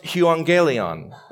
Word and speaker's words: euangelion: Well, it's pronounced euangelion, and I think it's kind of cool to see these Greek words.